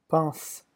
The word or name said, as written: pense